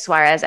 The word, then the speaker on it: Suarez: And then the last name is Suarez, S is in Sam, UAREZ.